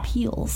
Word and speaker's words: Piel's: It's like it's spelled like PIEL'S. – Wow. – Peel's.